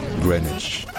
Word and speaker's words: Greenwich: Parce que l'orthographe anglaise indique que ça devrait se prononcer Greenwich. Sauf que ça ne dit pas du tout comme ça. En anglais, ça se dit Greenwich. Greenwich. Voilà.